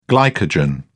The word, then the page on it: glycogen: British English